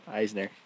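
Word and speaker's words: Eisnor: Eisner. – Isnor? How do you say his last name? – It's spelt O-R, but it's pronounced E-R.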